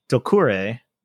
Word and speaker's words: Doucouré: Maimouna Doucouré. Doucouré? Correct.